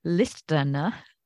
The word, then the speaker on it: listener: But as a thank you for listening to the end, if you use the code listener, I pronounced the T for some reason, listener, there will be a discount for you on Learn to Predict.